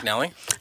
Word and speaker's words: McNally: McNally? – Yep. McNally.